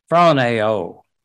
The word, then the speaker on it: phroneo: The words let and mind are translated from the same Greek word, 5426, spell P-H-R-O-N-E-O, pronounce, you know, P-H is like F, phroneo, phroneo.